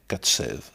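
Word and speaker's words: Kacew: de l'hébreu Katsei, prononcé en polonais Katsev.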